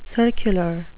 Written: circular

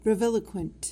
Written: breviloquent

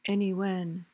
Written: anywhen

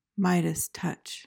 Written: midas touch

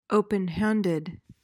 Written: openhanded

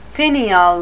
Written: finial

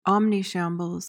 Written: omnishambles

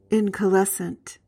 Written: incalescent

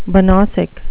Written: banausic

